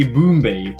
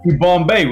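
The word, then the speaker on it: Yibambe: But the funny thing is, for the majority of people who see it, will call me Ibombe instead of, you know, Ibombay, which is how it's supposed to be pronounced.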